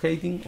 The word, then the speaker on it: hating: Eh, inmediatamente después voy a leer la cita de, Pleasure of Hating o ¿Se dice hating? ¿Estoy pronunciando bien? – sí. Bien, perfecto.